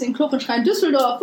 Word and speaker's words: Düsseldorf: Allerdings ist es in Düsseldorf. Und es ist das ist falsch ausgesprochen, Marina. – Ich bin gerade gerne in Düsseldorf. In Düsseldorf. – Düsseldorf. – Mit einem Seufzer und Augendreher. – Düsseldorf.